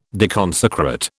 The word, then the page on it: deconsecrate: British English